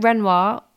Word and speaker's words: Ruinart: It would be maybe, I do like Renoir. I don't know if that's how you say it, but it's spelled R-U-I-N-A-R-T.